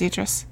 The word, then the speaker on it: Idris: Am I saying his name right? Idris? – I believe it's Idris.